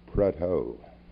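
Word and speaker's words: Prudhoe: And the Earl of Prudho, long since dead, was in the Royal Navy. And he and his descendants and his forebears pronounced it Prudho.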